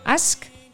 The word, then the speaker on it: ask: Bueno, ask, ¿cómo se dice? – Ask.